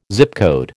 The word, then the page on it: Zip code: American English